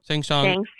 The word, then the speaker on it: Shang Tsung: And then number five, the actors who played Lord Raiden, Shun, is that how you pronounce the guy's name? – Shang Tsung.